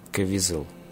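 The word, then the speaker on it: Caviezel: Там, кстати, играет Джеймс Кевизел, русское визел. Я не знаю, на какое ударение ставить, на какую букву ставить. – Я, я, я думаю, ты правильно сказал, да.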